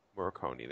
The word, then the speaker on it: Morricone: Morricone, there you go.